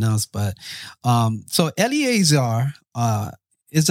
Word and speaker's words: Eliezer: Eliezer.